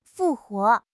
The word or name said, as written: 复活